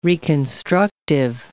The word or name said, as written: reconstructive